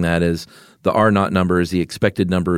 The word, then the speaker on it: R naught: Yeah, and that reproduction number, in the biz, it's pronounced R naught. It's R with a zero, huh? – Yeah. I'd rather say R0 any day of the week.